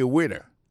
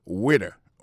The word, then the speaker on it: waiter: I am a waiter. Ça veut dire que moi, je suis un garçon, m'observer dans le restaurant. Alors moi, je prononce le waiter. Waiter, ça veut dire accent tombé sous le premier syllabe, waiter, W-A-I-T-E-R.